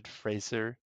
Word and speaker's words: Fraser: Fraser said Brendan Fraser. – Oh, no. – Frazier. I think he said in an interview, he's like, call me Fraser. That's how you pronounce it.